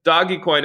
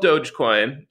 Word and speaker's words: Dogecoin: This doggy coin, it's actually called Dogecoin.